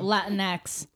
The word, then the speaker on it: Latinx: Oh, um, Latinx.